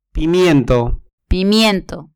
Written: pimiento

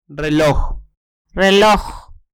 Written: reloj

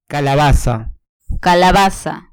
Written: calabaza

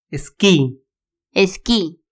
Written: esqui

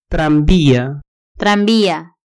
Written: tranvia

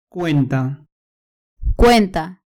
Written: cuenta

